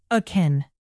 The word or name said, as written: akin